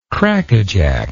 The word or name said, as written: crackajack